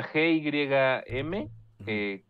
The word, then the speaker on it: Gymkhana: Jim Cana, GYM. Khana, Gymkhana.